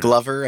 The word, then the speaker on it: GLVR: Glover. I heard people call it Glover.